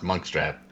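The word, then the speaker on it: Munkustrap: B, Monkastrap. C, Victoria. – Monkstrap. It's pronounced Monkstrap.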